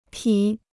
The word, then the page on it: 匹: pǐ